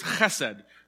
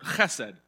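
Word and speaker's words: chesed (חסד): The word mercy in the Old Testament in Hebrew is chesed, pronounced with extra phlegm at the back of your throat, chesed.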